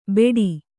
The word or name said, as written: ಬೆಡಿ